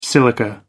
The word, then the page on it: silica: American English